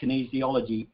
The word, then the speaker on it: kinesiology: So just for my own benefit, can you just explain what kinesiology is? And I hope I've got the pronunciation right. – Yeah, you have.